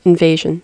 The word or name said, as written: invasion